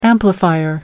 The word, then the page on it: amplifier: American English